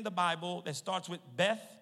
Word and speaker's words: Beth: B-E-T. Pronounced with an H in it. So it's really Beth.